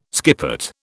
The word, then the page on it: skippet: British English